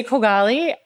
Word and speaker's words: Kogali: I said, we say Kogali. There's an Arabic pronunciation. I won't require you to say it. And Kumail said, I'm going to try it. What do you say? I said, you got to get the kh.